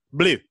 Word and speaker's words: Bleu: We're gonna take him out one by boy, and I, you have to say it a certain way because he's French. Yung Bleu. Can you say it like that, Drew? Blue, not blue, blue. Blue, like blue cheese.